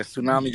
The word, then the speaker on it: Tsunami: Yeah, Tsunami, just like the wave. Yeah, so you said it right.